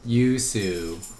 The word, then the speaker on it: Yoo Soo: Yoo Soo.